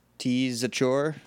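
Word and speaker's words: Tzachor: T-Zachor. – Yep. That's exactly how it's pronounced.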